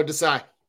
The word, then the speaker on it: Desai: Oh, Desai.